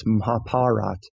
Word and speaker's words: Mahabharata: It's spelled Mahabharata, but, you know, people who speak Hindi have told me, hey, you're pronouncing that wrong. It's Mahabharata. A lot of the A sounds are dropped off at the end.